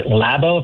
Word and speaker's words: Labau: Doctor, am I mispronouncing your name? Is it Labau? Labau? How do you pronounce your name? – Actually, Labo, if you want to be formal. – Labo, that is how I'd like to do it the right way.